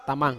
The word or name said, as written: tamang